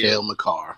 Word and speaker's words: Cale Makar: Cale, Cale Makar.